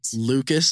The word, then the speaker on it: Lucas: Lucas, L-U-C-A-S dot F-R-A-N-K-L-Y-N, spelled the same way as Franklin by Sophia.